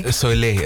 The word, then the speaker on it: Soleil: Zeg ik het goed? Route de Soleil? Oké. Ik ben Frans en ik kan het niet eens zeggen. Zeg het. Soleil. – Het is L-E-I-L, toch? – L-E-I-L, ja.